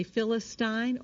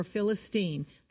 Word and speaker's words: Philistine: You may say Philistine or Philistine. There are British and American pronunciations.